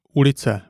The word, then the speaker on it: ulice: This is U, and it's pronounced like the U in look, and not like the U in urban. For example, ulice, meaning a street, or super, meaning superb.